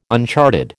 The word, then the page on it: uncharted: American English